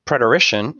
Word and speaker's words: preterition: Preterition, and you pronounced it perfectly fine as far as I know it to be pronounced, preterition means to pass by or to go beyond.